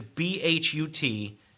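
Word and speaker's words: Bhut jolokia: Then in 2007, I keep on calling it the Buttjorkia. – I've seen Buttjorkia. – Pronunciation as Buttjorkia. – The Buttjorkia. Right. So it's not the Buttjorkia. – I don't think it's the Buttjorkia. – But it's Buttjolkia.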